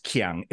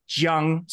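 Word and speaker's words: Jiang (江): But if you want to say K-I-A-N-G on Deep Space Nine is river, but it's not pronounced Qiang, it's Jiang. So it's a J sound.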